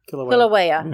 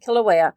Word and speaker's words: Kilauea: Kilauea. – Yeah. I keep forgetting how to say it. Kilauea.